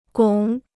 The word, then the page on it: 拱: gǒng